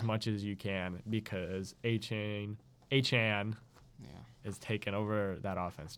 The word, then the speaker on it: Achane: You don't pronounce it like that. If you watch the game, I watched the game. – Devin Achane. Never in my life would I expect it to be pronounced like that.